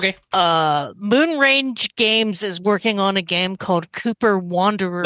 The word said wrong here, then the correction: Kuiper: Kuiper. It's an astrology thing. Don't worry about it. Nobody knows how to say it the first time.